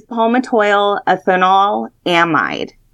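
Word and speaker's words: palmitoylethanolamide: It's palmitoyl ethanol amide.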